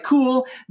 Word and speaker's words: KUL: Their cool i hope i'm saying this right their kul acronym sound looks like cool